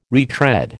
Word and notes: retread: American English

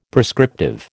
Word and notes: prescriptive: American English

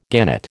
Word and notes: gannet: American English

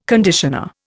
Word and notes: conditioner: Indian

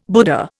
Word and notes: Buddha: Indian

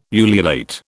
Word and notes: ululate: British English

